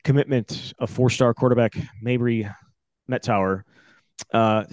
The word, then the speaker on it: Mettauer: Mettauer.